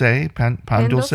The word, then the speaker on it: pan dulce: They were asked to make, again, I don't want to mispronounce, is it pan dulce? Pan dulce? – Yeah.